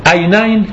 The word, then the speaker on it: عینین: عینین که تو فارسی عینین تلفظش میکنیم، مثنای عینه.